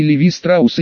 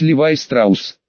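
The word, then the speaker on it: Levi Strauss: И Левис, и Левайс, что ближе к оригиналу, и Леви Страус, и Леви Стросс, по имени отца-основателя компании, которого правильнее было бы именовать Левай Страус.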